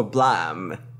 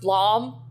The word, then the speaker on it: Blom: You're, you're, you're not working for Blam. Is, is the long and short of it? – It's, it's pronounced Blom, doctor blah.